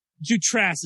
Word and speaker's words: Jutras: Jutras is how it's pronounced, is how it's spelled, and it should be how it's pronounced because that's how it's spelled.